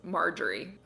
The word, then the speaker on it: Marjorie: I think the way that Taylor pronounces it is Marjorie. – Okay. – Sounds a lot like margarine to me.